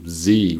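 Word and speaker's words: Xe: The most famous one is called Blackwater, the name has been changed to Z, which is spelled X-E, which is pronounced Z.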